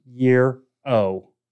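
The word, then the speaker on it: gyro: It says the mighty, mighty Yee-ro, pronounced Yee-ro.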